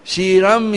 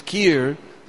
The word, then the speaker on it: kheer: Shiram yathadari vikara vishesha yogat. Shir means milk. Just like we know Shirodakshai Vishnu. But the Bengali pronunciation is kheer, so sometimes we become confused.